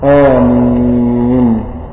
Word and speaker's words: آمين: امين تنطق بمدين او مد واحد، والمعروف في لغه اهل مصر المد الواحد. آمين لأنه مد البدل اللي فيه اللي بعد الهمزة، ليس في قراءة حق اللي هي منتشرة عند المصريين، في قراءة ورش ولا غيره، فالأفضل أن المأموم يقول آمين ألف بعد الهمزة كده من غير مد، ده أفضلها.